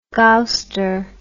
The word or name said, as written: gowster